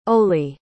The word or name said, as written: Oley